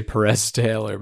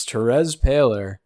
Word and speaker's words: Terez Paylor: I feel like that's a spoonerism and I'm saying that wrong. I want to say Perez Taylor, but it's Terez Paylor passed away.